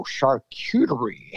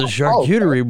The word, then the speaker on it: charcuterie: I kept calling them a cutting board or a cheese board, and everybody's like, no, charcuterie. – There you go. Like, it's a charcuterie board.